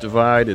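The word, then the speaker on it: ÷: Okay, this is Megan Borowski's final song today, Castle on the Hill by Ed Sheeran from his 2017 album, Pronounced Divide.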